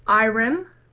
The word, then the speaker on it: Irem: I always say this wrong. It's Irem. – Okay, I-R-E-M. – I-R-E-M. I'm pretty sure that's how it's pronounced.